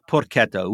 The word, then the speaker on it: porchetta: Okay, so porchetta is spelt with an H, right? Like Chianti, when you have the CH combination, it modifies the sound. So you've got porchetta, which is a spit roasted stuffed pig.